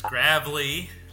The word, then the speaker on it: Gravley: It is well, the cyclists will appreciate this. – Gravelly, like a gravelly path or road or gravel biking.